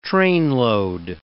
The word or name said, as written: trainload